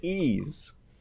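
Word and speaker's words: Ys: Ease.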